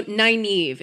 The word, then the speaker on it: Nynaeve: So Nynaeve is how they pronounce it in the show.